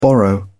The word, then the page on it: borrow: British English